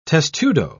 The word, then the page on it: testudo: British English